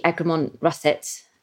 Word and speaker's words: Egremont Russet: egremont russet Am I pronouncing that pro- properly? – Yes, absolutely.